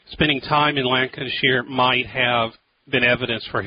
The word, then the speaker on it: Lancashire: Is it Lancashire? – Yeah, there is a body. – Is that how you pronounce it? – Lancashire.